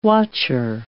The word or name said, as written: watcher